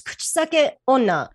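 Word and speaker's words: Kuchisake-onna: How do you say Kuchsakione? – Yeah, that's pretty much it. It's Kuchsake Onna.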